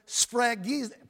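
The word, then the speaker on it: Spragizo (σφραγίζω): You know, when you look at this word seal, in the Greek it comes from the Greek word spragizo. I'm pronouncing that right. Spragizo. It's spelled spragizo. It's pronounced spragizo.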